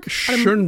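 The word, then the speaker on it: Schoenbrun: And people should check out, if you didn't see Jane, is it Schoenberg? Schoenberg? – Schoenbrunn, I'm saying her name right.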